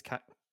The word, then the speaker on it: Karai: Is it Karai it's pronounced? – Yeah, I think it's Karai.